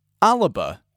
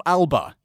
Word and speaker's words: Alba: ALBA is how you say it. – Yeah. – It's not ALBA. – Yeah. – That is the English pronunciation of a Gaelic word.